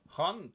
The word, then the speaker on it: Haunt: Hunt. – Pardon. Hunt. Hunt. Mais c'est H-A-U-N-T.